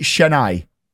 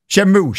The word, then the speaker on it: Shenmue: Is it Chennai? – Is it the game? – Shenmue.